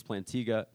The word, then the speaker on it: Plantinga: A Breviary of Sin by a philosopher named Cornelius Plantinga. That's how we pronounce it. Yeah, Plantinga. And he talks about sin as the vandalism of shalom.